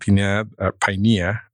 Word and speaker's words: Pinea: You mentioned Pinea, as it's pronounced here, P-I-N-E-A.